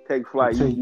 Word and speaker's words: TayKFlight: So, bro, so TayKFlight, or taking flight. – TayKFlight. You said it right.